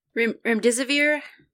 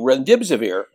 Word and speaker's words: Remdesivir: And by the way, with I hope I pronounced it right. Ritonavir, that drug? – Remdesivir, thank you. Remdesivir.